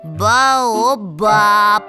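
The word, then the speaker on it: баобаб: А в слове «ба-баб» какой слог выделяют? «Ба-о-баб». – Третий! Последний!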